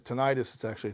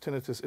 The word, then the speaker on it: tinnitus: Some people pronounce it tinnitus. It's pronounced tinnitus.